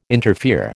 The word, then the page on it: interfere: American English